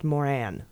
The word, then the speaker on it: Moran: Senator Boozman is pronounced Bozeman, and moron is pronounced Moran, though I quite prefer Boozman and moron.